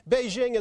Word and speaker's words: Beijing: Spelled with a J but pronounced with a Z, Beijing is rich in history but still shrouded in mystery.